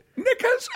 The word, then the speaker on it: knickers: It's like he said when you say knickers, and I said, but the K is silent.